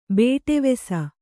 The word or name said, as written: ಬೇಟೆವೆಸ